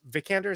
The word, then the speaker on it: Vikander: I mean, uh, Alicia uh, Vikander is how you say her last name.